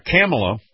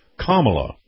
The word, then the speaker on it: Kamala: Right after that, Kamala, or wait a minute, let me say this right, because I'm not saying her name right. Kamala, there we go.